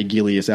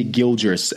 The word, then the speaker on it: Gilgeous: Somebody tried to get on my ass about saying Shea Gillies Alexander wrong. It's Shea Gilgeous Alexander, I guess.